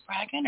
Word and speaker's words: Spraggon: Was it Spraggon or Spraggon? You did. – I did. – Spraggon, rhymes with dragon.